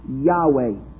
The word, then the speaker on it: Yahweh: It was spelled by the consonants YHWH. We think that it is pronounced Yahweh.